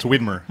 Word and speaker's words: Widmer: It's Widmer. Wait, it's it's weird. I mean, it's uh, W I D M E R. It doesn't actually look like Widmere, but um, you know when people start pronouncing something away, then everybody does, and so it's Widmere in Portland. But the poor brothers always try to correct people.